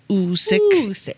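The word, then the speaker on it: oosik: Usyk is correctly pronounced as Usyk.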